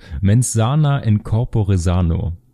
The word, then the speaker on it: Mens sana in corpore sano: Mens sana in corpore sano.